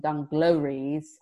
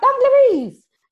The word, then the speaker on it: dungarees: I'm sad that for many, many years, I mispronounced the word dungarees, and I always used to call them dunglories. And my husband was the one that noticed. He was like, sorry, what? I'm like, dungarees.